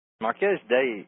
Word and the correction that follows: Marquis de Sade: and I may be pronouncing this wrong, but... Marquis de Sade, Marquis de Sade, Marquis de Sade.